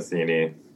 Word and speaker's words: Metheny: Pat Metheny. – Can you spell it? – Pat, and then Metheny is Methenny.